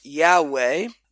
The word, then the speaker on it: Yahweh: It corresponds to the holy name of God in Hebrew, which we would say in English, most commonly, it is pronounced Yahweh.